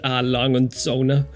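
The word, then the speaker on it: Lange & Söhne: And Lang and Zona. although I actually, just that was perfect pronunciation there, so no one will correct me on it.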